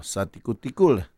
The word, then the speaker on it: 萨迪古迪古勒: 好，然后他的专辑名称，大家要听他正确的发音才是对的。专辑名称叫做，我的专辑名称叫做《萨迪古迪古勒》。